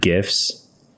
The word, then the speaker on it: GIFs: And I figured out how to make animated GIFs. And yes, I do pronounce it with a hard G.